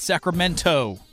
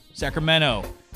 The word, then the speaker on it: Sacramento: Sacramento. Not Sacramento. Sacramento. Us Californians have an accent.